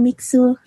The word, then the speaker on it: Mixue: Eh, yang bacanya yang bener Mixu atau Mixu sih? – Eh, Mixu. – Mixu. Mixu yang benar Mixu.